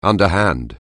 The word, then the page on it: underhand: anglais